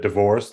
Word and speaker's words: DeVore: No, it's French, but everyone since I've been born pronounced it divorce. That's the way I go with it.